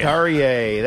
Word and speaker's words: Carrier: Carrier, that's right.